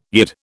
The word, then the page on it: Yid: British English